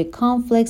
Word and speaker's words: Conflict: Constructive Conflict به تلفظ دقت کنین، هر دو با CO شروع میشن ولی در ابتدای Constructive ما صدای شوا رو داریم ولی در ابتدای کانفلیکت صدای آ رو داریم.